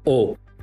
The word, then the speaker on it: aux: AUX, ça se prononce O.